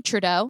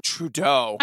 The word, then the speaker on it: Trudeau: Trudeau. – Trudeau.